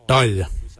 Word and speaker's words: dhaoly: Your quarn is spelled G-W-A-N. Quarn is your backside. Thigh, we say dal. Dal. Dal is spelled D-H-A-O-L-Y.